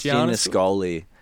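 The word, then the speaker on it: Giannascoli: So Alexander Gianoscoli. – I think it's Gianoscoli.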